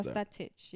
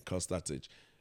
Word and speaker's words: Costa Titch: I think it's Titch. – Yes. – I don't want to pronounce the name incorrectly, but I always use the Costa. – Costa Titch, yes. – Yeah, Costa Titch.